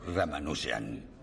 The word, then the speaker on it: Ramanujan: Bienvenido al Trinity, señor Ramanujan.